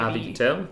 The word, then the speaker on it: Harvey Keitel: Harvey Keitel.